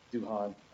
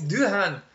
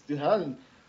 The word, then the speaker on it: Doohan: And seeing James Doohan in something, anything other than Star Trek is Doohan. I said it wrong. Sorry, guy. – Scotty! you pronounced my name wrong. It's Doohan. – It's Doohan.